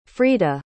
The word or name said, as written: frieda